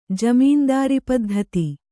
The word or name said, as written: ಜಮೀನ್ದಾರಿಪದ್ಧತಿ